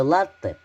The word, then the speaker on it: латте: Да, слово «в просак» пишется слитно – Ударение в слове «латте» падает только на первый слог.